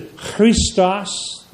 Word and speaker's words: Christos: That's how they would have said in those days, because the first letter, CH, for Christ is pronounced Christ, Christos. Can you do it? Christ, Christ. It's a guttural.